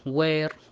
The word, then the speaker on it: where: يعني الآن عندنا كلمة اللي هي what, where, when, why, which، كل أتى فيها حرف H لكنها تكتب ولا تنطق إذا أتى قبلها حرف الدبليو.